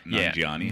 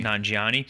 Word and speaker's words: Nanjiani: Nanjiani. – Nanjiani.